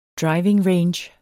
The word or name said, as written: driving range